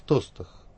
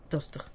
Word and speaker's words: тостах: На самом деле, как мне кажется, квинтэссенция смысла вот в этих тостах. Или тостах, как правильно? – Тостах. – В этих тостах.